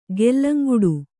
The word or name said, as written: ಗೆಲ್ಲಂಗುಡು